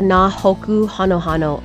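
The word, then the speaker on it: Nā Hōkū Hanohano: Yeah, it's the Nahoku Hanohano Awards. – Okay. – Thanks. I want to hear your pronunciation. – Nahoku Hanohano? – Yes, okay. Perfect.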